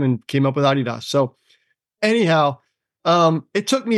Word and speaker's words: Adidas: Is that how you say it? Adidas? – In Germany, yeah.